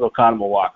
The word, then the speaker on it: Oconomowoc: And from that moment on, I knew it was Oconomowoc.